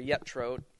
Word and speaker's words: Jethro: Because Moses has gone back to his father-in-law, Jethro, or Yetro, is I think the more Hebraic pronunciation.